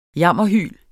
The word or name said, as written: jammerhyl